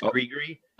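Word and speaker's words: gris-gris: It's G-R-I-S-G-R-I-S. It's pronounced gree-gree. – Oh, is it? Okay, gree-gree.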